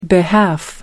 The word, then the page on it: behalf: American English